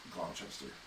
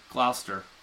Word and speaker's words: Gloucester: That's Gloucester. – Gloucester. I'm telling you, it's how it's pronounced.